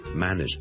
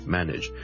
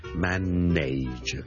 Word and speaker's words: manage: 今日想同大家讲 manage 呢个字嘅发音，manage M A N A G E manage。由于呢个字后面 N A G E 咧，nage 咧系串做 N A。好多人就会将它读成 manage 嘅，咁但系事实上咧，我呢个字咧系读 manage nudge nudge nudge，你当系好似 n i d g e 咁读，OK manage，譬如 an establishment that is managed very well，呢个地方咧系 managed run 好好嘅 manage，或者 he's the manager 亦都系 he's the assistant manager assistant 呢个助理经理。